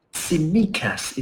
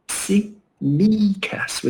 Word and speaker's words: Tsimikas: We got Andy Robertson, the Scotland captain, and Kostas Tsimikas is the pronunciation according to a Greek guy on Twitter who wrote it phonetically. Tsimikas, with a stress on the middle syllable, okay? Tsimikas.